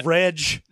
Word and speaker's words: Reg: Now, oh, Reg. – Now, Loam Reg, which is spelled like ledge with an R,